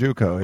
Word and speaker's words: Uko: This big kid, how do you pronounce the name, number 90, Urko? – George Uko. Yeah. – Uko.